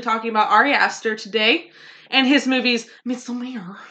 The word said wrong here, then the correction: Midsommar: It's actually Midsommar. – It's Midsommar. – Don't ask me why. When I looked at it, I just started pronouncing it like that. And everybody's like, that's not how you say it.